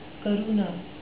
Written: karuna

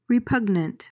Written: repugnant

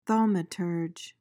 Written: thaumaturge

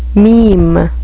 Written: meme

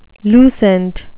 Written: lucent

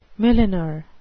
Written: milliner

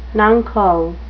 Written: nuncle

